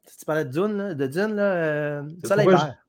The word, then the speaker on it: Dune: En anglais, Dune.